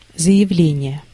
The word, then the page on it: file: American English; [faɪl]